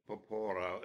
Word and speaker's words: Porpora: And I remember in class saying that Antonio Porpora, which is, for me, Porpora, it would be the way to pronounce it. When I learned Italian, I was always told to, if you don't know where the accent goes, it's probably going to belong to the penultimate syllable.